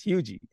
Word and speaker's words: Huger: they always say Hugie. So every time anybody calls me, it's Hugie. So that's how my last name is pronounced.